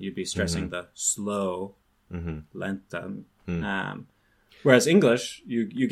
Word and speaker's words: lentamente: And so, like with adverbs, lentamente, which is the word for slowly, rapidamente, the stress is on just this adverbial ending that doesn't have any meaning besides this is an adverb.